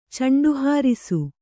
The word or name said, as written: ಚಂಡುಹಾರಿಸು